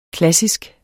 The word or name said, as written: klassisk